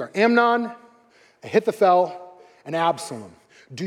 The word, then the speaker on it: Ahithophel: I had to watch this video like five times, but I think I got it. Ahithophel. Amnon, Ahithophel, and Absalom.